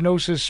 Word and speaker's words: Gnosis: Gnosis, as you said, you know, this is Gnosis spelled with a G.